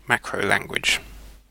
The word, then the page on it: macrolanguage: [ˌmækɹəʊˈlæŋɡwɪʤ]; British English